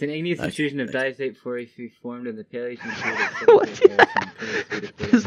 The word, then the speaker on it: porphyry: Porphyry, Brian. Okay, seriously, that's how you pronounce it. – There's no P. There's no PH porphyry.